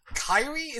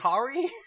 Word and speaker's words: Kari: I let go of you calling her Kairi instead of Kari.